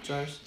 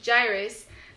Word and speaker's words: Jairus: Because and it starts with the parents too, because it started with this man, Jairus, I can't say his name for the life of me. – Jairus. – Jairus, who decided to go look for Jesus and believed that Jesus could heal his daughter.